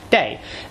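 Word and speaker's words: day (茶): In Minanhua, the pronunciation of this character that means tea is day.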